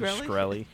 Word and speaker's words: Shkreli: Oh, Shrelly. What's his name? – Martin Shkreli.